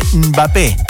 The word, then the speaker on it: Mbappé: En vez de Mbappé, sacale la E. Mbappé, ¿sí? Es así, Mbappé, con la lengua en el paladar.